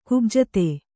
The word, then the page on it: ಕುಬ್ಜತೆ: Kannada